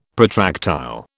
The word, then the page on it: protractile: British English